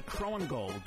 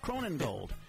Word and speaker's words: Krohnengold: You know, I never knew your Jake, I want to say Krohnengold, but I have a feeling I'm pronouncing it wrong. – You are exactly pronouncing it wrong. It's Krohnengold. – Krohnengold.